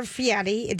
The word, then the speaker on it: Fieri: Guy Ferrari, you guys know who he is? – Yes. – The chef? – Guy Fieri? – Or Fieri. There's no T in it.